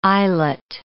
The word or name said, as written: eyelet